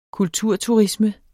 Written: kulturturisme

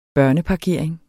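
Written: BØRNEPARKERING

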